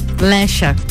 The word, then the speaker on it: Lexa: Agora para você aqui na RC7, Lexa quase lá um feat com um sorriso maroto que fica essa combinação muito gostoso. Desculpa, foi Lecha. Lecha? – Lecha. Ah, se pronuncia Lecha o nome dela.